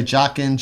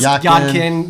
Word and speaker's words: Jakin: J-A-K-I-N. Some people say Jokin, Jakin. – It's pronounced Yadkin. Yeah, Yadkin.